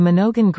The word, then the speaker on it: Minogin: The Monongan Greenhouse, pronounced M-I-N-O-G-I-N, with a hard G, and all should be complete early in the new year.